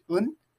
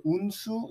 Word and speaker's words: Eun: the vowel doesn't really exist in English, Eun, a lot of people pronounce it Eunsu, it's not Eun, it's Eun, but, you know, yeah.